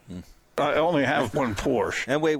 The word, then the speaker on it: Porsche: It's pronounced Porsche. – It is pronounced Porsche.